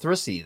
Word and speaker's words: thrussy: It should be pronounced thrussy then.